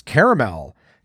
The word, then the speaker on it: caramel: Caramel is the original way and still the preferred way. Although caramel, which was once a Midwestern regional pronunciation, is also acceptable.